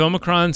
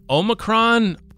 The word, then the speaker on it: Omicron: I feel like it should be Omicron since it's Omega. – Yeah. – But I can't stop myself from saying Omicron. And apparently that's the more common American thing.